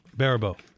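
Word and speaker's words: Baribeau: Mike, you're going to have to help me out with the pronunciation of the last name of our first guest, Rachel Barubo? Barubo.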